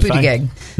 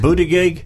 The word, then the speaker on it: Buttigieg: How's he pronounce his last name there? You're good at this. – Buttigieg. – Is it Buttigieg? – Buttigieg. – Buttigieg. – Booty something. – Buttigieg, Buttigieg, whatever the guy's name is.